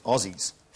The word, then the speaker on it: Aussies: No, well, even though it's written with an S, we pronounce it with a Z, and we pronounce it Aussies.